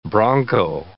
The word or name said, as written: bronco